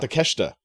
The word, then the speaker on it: Takeshita: Kanosuke Takeshita. – Takeshita. – Yeah. – Yeah. – You kind of have to just put the S-H-I in one syllable. Like, just, it's like half a syllable.